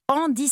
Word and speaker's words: Endix: Alors, moi, je voudrais recommander comme adresse secrète le restaurant Andy Saveur. C'est un jeu de mots, ça s'écrit E-N-D-I-X, saveur au pluriel. – Oui. – Mais c'est un jeu de mots avec Andy, H-A-N-D-I, comme handicap, un peu comme sur le modèle des cafés joyeux.